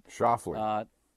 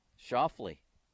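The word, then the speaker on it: Schauffele: Was it Scheffele? Is that how you say his name? – Scheffele. – Scheffele.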